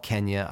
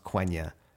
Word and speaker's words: Quenya: It's called, I call it Kenya. I know it's technically still pronounced Quenya. It's Q-E-N-Y-A.